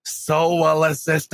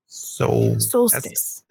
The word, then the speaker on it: solstice: The soul assist dice is in a week, so we still got some time. – Solstice. – Solstice.